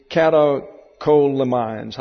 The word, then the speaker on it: catecholamines: It is these granules that contain the catecholamines. How do you say that? Catecholamines, not mines.